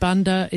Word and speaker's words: Banda: So I should tell everyone that Banda is spelt B-A-N-D-A, the Banda Islands, not the Bunda as in B-U-N-D.